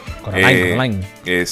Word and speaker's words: Coraline: Coraline.